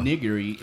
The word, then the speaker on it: nigiri: Nigiri is the sushi. Onigiri is the rice ball.